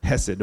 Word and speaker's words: hesed: I put pronounced hesed without the C, because you could just read that as chest. And the word is hesed.